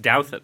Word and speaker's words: Douthat: And it is Douthat. Thanks for having me back. It's Douthat. – Because you have probably the most mispronounced prominent name in American life, other than Alexandria Ocasio.